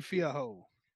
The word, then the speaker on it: Fiorot: and the French, oh my lord, Fiorot, I can never say it. – Fiaho. – I can never. Thank you. – Manon Fiaho.